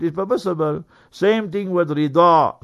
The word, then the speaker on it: Ridha: So anyway, if you keep the name Rida, is with a dod. So R-I-D-H-A. So Rida. You must pronounce it correctly also.